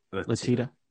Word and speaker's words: Letitia: Letitia.